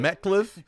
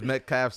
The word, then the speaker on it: Metcalf: Call him Metcliffe, but it's Metcalf, something like that.